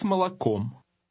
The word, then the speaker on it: молоком: С маслом и с молоком. – Notice the difference in pronunciation between the O's in the last word.